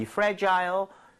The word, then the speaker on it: fragile: 다시 한번 이 fragile, 둘 다 발음 괜찮습니다. Fragile은 조금 더 미국에 가깝고 Fragile, 영국에 가깝지만 요즘에는 다 섞어서 쓰는 편이에요.